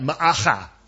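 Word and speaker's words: Maacah: Try that, Ma'acha. Because you want to pronounce every letter.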